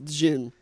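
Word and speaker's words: Djinn: And they're facing, I believe it's pronounced Jin? – Dijon mustard. – No, it's Jin. – It's Jin? – Yeah. – That's what I said, Jin. – Like, there's a D, but it's very, very subtle.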